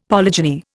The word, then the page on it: polygeny: Indian